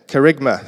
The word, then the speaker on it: Kerygma: Kerygma, thank you, there it is.